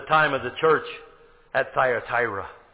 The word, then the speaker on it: Thyatira: T-H-Y-A, Thya, right? Am I spelling it right? Let me make sure I look. Yeah, T-H-Y-A-T-I-R-A, Thyatira. That is the church of the Dark Ages.